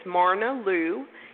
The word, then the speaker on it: Myrna Lou: It's Myrna Lou, M-Y-R-N-A, Lou, L-O-U. Did I say it wrong? – No, you said it right.